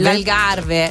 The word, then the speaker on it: Algarve: Allora, non so se si pronuncia, correggimi tu, l'Algarve. – L'Algarve. – Ok, l'Algarve, sì, okay.